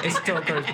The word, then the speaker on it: Darkstalkers: sí, la L no se pronuncia, güey. – Stalkers.